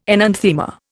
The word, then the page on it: enanthema: Indian